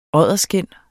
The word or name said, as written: odderskind